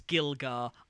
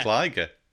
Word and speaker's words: Gligar: But I mean, I also mispronounce Gligar in Pokemon as Gilgar, and I can't help it. – Gligar. I call him Gligar.